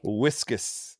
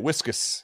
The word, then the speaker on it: Whiskas: Whiskus. That's how you pronounce it. That's how we say. Chan, pull up the commercial. That's how you say it, whiskus.